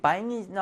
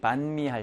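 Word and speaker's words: bánh: 예를 들어서 여기 바잉이 나왔죠? 이 바잉이라는 게 우리가 베트남 여행 가면 아침에 먹는 것이 반미 할때그 반을 바잉이라고 하는 거야. 지역에 따라서 발음이 조금씩 달라요. 북부에서는 바잉, 그리고 남부에서는 반 이렇게 하니까 지역에 따라 좀 달라.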